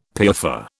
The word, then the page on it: piaffer: British English